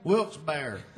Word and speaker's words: Wilkes-Barre: In Wilkes-Barre, Wilkesburg. Which one is it? What? Does anybody know the correct pronunciation of this town we're in? – Wilkes-Barre. Wilkes-Barre. If you say somewhere in between, somewhere Westbury.